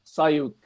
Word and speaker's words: Sioux: and then he went Sioux. – Yeah, that's a bit of a giveaway, mate, isn't it? It's not pronounced how it's spelt.